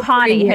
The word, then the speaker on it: Ojani: Ohani. His name is Ohani. You don't pronounce the J. Or you do pronounce it, but you pronounce it as an H. Come on, don't you know? It's Ohani.